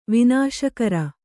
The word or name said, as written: ವಿನಾಶಕರ